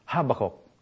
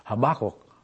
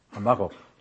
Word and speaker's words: Habakkuk: When Habakkuk prophesied, when I was in the Philippines, by the way, I pronounce this Habakkuk. That's the way how we, our accent there. But here, I have to change it to, and look it up in the dictionary, it's Habakkuk, Habakkuk. So if I go into a first syllable or second, you'll understand we are there.